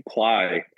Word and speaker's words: Plai: Is it pronounced Ply? – Ply, actually. I think it has some meaning in Ukrainian, I believe. – Spelled P-L-A-I. Now, they call themselves a people and performance management tool.